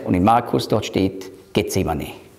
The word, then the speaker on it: Gethsemane: Auf Hebräisch würde man schreiben, Gat Shmanim. Auf Griechisch gibt es kein Sch.